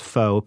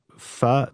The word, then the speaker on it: pho: One thing that you got to have is it's called pho, and most people pronounce it as pho, P-H-O, but it's pronounced pho, and it's kind of a beef noodle soup.